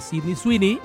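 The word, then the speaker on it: Sydney Sweeney: Manden la mejor pregunta que le quieran hacer a Álvaro Morte o a Sidney Sweeney.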